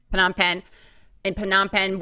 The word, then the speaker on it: Phnom Penh: Phnom Penh, where a tiny camera was inserted into his penis, which revealed the culprit to be a leech.